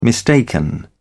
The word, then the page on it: mistaken: British English